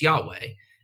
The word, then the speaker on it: Yahweh: But it is interesting because a lot of the scholars – a lot of the Old Testament scholars who aren't Jewish will say, oh, no, it's Yahweh.